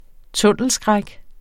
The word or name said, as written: tunnelskræk